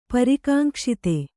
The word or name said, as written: ಪರಿಕಾಂಕ್ಷಿತ